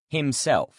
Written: himself

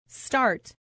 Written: start

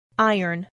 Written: iron

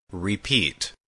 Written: repeat

